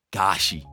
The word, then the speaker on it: G4SHI: Gashi. You spell it G4. So G, the number 4. S-H-I. Gashi. – How do you say that shit? – Gashi.